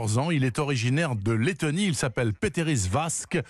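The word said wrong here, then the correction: Pēteris Vasks: prononcez toutes les lettres, hein, donc Peteris Vasks,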